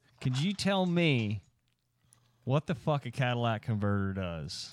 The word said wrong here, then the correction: catalytic converter: So, first you have to say it right. – The catalytic converter.